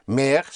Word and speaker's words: Meer: Meer spelled for ocean, M-E-E-R, Meer. But you have the same word Meer, which means more, and that is spelled M-E-H-R. So the sound is the same. Meer, more, M-E-H-R, or Meer, ocean, M-E-E-R, Meer. So sehr is spelled S-E-H-R.